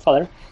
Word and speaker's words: vader: porque en holandés la palabra father o vader, pero se pronuncia father, significa padre.